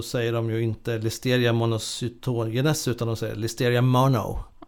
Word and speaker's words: monocytogenes: Ja, monocytogenes brukar jag ofta säga. – Det ligger i vardagssnack. – Men jag, de som förstår sig på brukar nog säga någonting annat skulle jag säga. Monocytogenes snarare. – Monocytogenes. – Med lite betoning där i mitten.